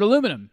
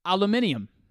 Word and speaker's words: aluminium: And guys, I've had it wrong for 34 years, almost 34 years. The way the Brits spell it, the original word, is the way they say it, aluminium.